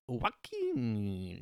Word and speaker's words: Joaquin: Hold on, I didn't pronounce it correctly. Joaquin. Yes. Like, sounds like an Asian, like, weird food item.